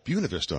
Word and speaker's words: Buena Vista: Yeah, it's and that's Buena Vista. They actually pronounce it Buena Vista. – Oh, Buena Vista.